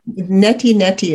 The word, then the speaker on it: neti neti: Neti neti is how they say it in Eastern neti, philosophy.